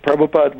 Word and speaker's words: Prabhupada: Was there a great deal of sort of turmoil that happened after Prabhupada died? Did I say that right? – Yes, you were pretty close. Prabhupada,